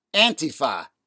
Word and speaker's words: Antifa: You may have noticed what I've been saying Antifa very pointedly, pronouncing it that way, the old school way. When the term first came into widespread currency, maybe about a generation ago, that was the accepted pronunciation, Antifa. Increasingly, the newfangled pronunciation of Antifa is becoming ubiquitous.